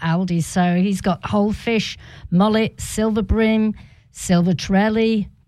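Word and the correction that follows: trevally: Trevelli. I know, yep. Trevelli.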